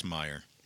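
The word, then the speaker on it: Meijer: Anyway, getting back to Peter Meyer. So I think that's how you say his name. It's M-E-I-J-E-R. Yeah. I think it's Meyer.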